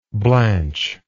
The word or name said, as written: Blanche